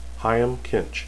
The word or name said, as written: hyam